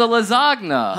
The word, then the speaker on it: lasagna: You know the G's not silent. – Yeah. – It's a lasagna. – Huh? – A lot of people don't pronounce that correctly.